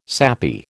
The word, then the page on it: sappy: American English